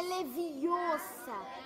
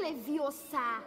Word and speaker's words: Leviosa: É leviosa e não leviosa.